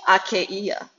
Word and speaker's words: Achaia: You know that the household of Stephanas were the first converts in Achaia. I wanted to pronounce it with the Greek. I've been learning Greek, guys. So, Achaia. That's how you say it in Greek.